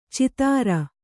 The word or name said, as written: ಛಿದ್ರ